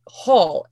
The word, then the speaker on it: Hall: But my regular last name, the last name I was born and raised with, Hall, H-A-L-L, People still say it wrong. – Like, I'm going like, down the hall.